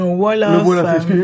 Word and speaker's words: Wolof: Donc, ça permet aux enfants de sympathiser avec plus que d'une culture, parce que quand tu parles différentes cultures, tu dis, tu parlais le Zolof, toi. – Le Wolof. – Excuse-moi, pardon.